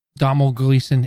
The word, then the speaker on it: Domhnall Gleeson: Domhnall Gleeson.